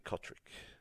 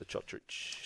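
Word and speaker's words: Cotric: Breaking news, Nick Kotrick wants to be known as Nick Kotrick again. So forget the Chotrich.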